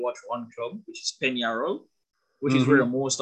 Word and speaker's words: Peñarol: P-E, it's spelled Peñarol, but it's pronounced Peñarol.